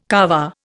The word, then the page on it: Kaaba: Indian